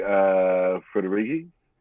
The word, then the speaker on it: Federighi: Anyway, Apple software chief Craig Federighi. – Yep. – Defends. I said it fast enough, so I said it right.